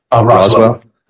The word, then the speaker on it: Roswell: Like, for example, I just saw a property, I think in Roswell. Did I say that correctly in your area? – Roswell. – Yep. Roswell.